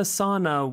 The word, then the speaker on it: sauna: The sauna wasn't really my thing. – 아, 사우나. 사우나가 영어네요, 그렇죠, 여러분? 그런데 발음은 sauna라고 해요.